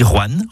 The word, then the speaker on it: Rouen: Vous avez remarqué comment je dis Rouen en une syllabe plutôt que Roanne en deux syllabes? C'est comme ça qu'on y dit dans la région.